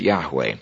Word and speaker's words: Yahweh: and the correct pronunciation of the divine name is Yahweh. Y-A-H-W-E-H, or sometimes spelled J-A-H-V-E-H, but in either case it is pronounced as Yahweh, or as the Israeli pronunciation would make it Yahweh.